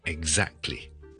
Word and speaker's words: exactly: 上一集我哋讲咗 example 呢个字，仲有啲咩字系会有呢个 example 呢个 G Z 嘅发音咧？最常听到咧系 exactly, E X A C T L Y 呢个我哋以前都讲过啦。 Exactly, exactly, exactly 嗰个 G Z 咧系 voice 嘅，eggs 怎么 exactly？